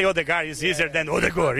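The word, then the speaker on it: Ødegaard: What about Odegur? Odegar? – Odegor. – Odegur? – Yeah. – In Brazil, we say Odegar.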